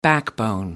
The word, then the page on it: backbone: American English